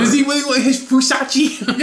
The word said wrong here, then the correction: Versace: It's Versace. I can't afford it, so I don't know how to pronounce it. – With a fucking V. – Okay. Versace.